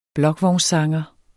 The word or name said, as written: blokvognssanger